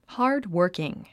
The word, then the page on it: hardworking: hɑ́ːrdwə́ːrkiŋ; ハードワーキン